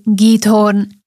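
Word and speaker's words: Giethoorn: Za jeden z najpokojnejších kútov Európy môžeme považovať mestečko, dedinku, ktorá podľa toho, ako som sa dočítala, sa vyslovuje Heathorn, alebo vraj po slovensky to niekedy je aj Githorn.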